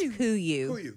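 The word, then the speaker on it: KUIU: It's pronounced Kuyu.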